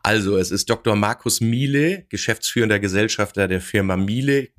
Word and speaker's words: miele: Miele wird es geschrieben, ausgesprochen wird es natürlich Miele.